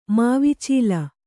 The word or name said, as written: ಮಾವಿಚೀಲ